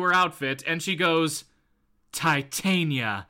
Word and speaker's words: Titania: I do, and I prefer the pronunciation with the tit in it. – All right, we'll go with that then. We'll go with Titania.